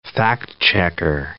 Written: fact-checker

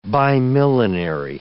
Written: bimillenary

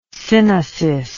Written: synesis

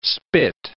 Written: spit

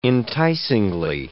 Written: enticingly

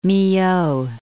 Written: Milhaud

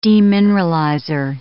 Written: demineralizer

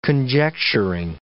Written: conjecturing

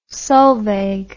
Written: Solveig

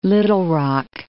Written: Little Rock

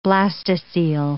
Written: blastocoel

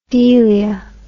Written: delia

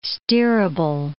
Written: steerable

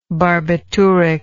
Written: barbituric

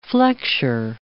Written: flexure

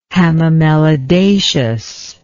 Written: hamamelidaceous

